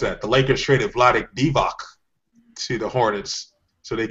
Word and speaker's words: Divac: I'm glad you pronounced that correctly. – Because no one else has through history. – No one else in sports history has pronounced his name. – Oh, gosh. Are we talking about Bob Costas now? – Not even Bob Costas said his name correctly. – Then I might like Bob Costas if he didn't say his name correctly. – I've heard Vlade DeVos.